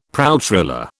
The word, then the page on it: pralltriller: British English